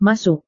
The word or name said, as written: masuk